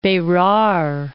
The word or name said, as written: Berar